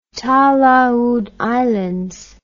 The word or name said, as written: talaud islands